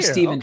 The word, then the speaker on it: Stephen: And so I'm Stephen T.